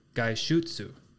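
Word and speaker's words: gaishutsu (外出): Outing is gaishutsu, and that is spelled G-A-I-S-H-U-T-S-U.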